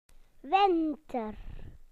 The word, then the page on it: winter: British English